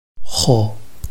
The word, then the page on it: hou: hou5